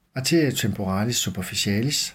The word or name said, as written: a. temporalis superficialis